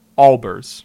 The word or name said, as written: Albers